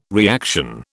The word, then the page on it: reaction: British English